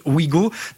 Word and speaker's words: Ouigo: Ici aussi, ça se prononce Ouigo.